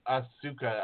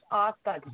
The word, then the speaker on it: Asuka: They pronounce it how it's spelled. It's Asuka, darn you.